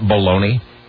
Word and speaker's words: Bologna: Why do Americans pronounce that baloney? Is that... – It's an Oscar Mayer thing. – But, I mean, is that... Why do we do that in this country? Why do we take the word B-O-L-O-G-N-A and pronounce it baloney? – We used to call it Bologna when I was younger.